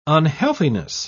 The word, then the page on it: unhealthiness: British English